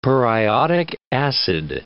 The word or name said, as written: periodic acid